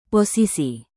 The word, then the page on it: posisi: indonesian